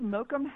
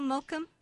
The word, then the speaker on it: Mokum: And how would you spell Mokum? – M-O-K-U-M. – Oh, thank exactly. – you. M-O-K-U-M. – Yeah, rhymes with hokum.